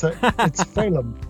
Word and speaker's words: Féidhlim: It's a really good effort. It's Féidhlim. – Féidhlim, okay. – Rhyming with Winston-Salem.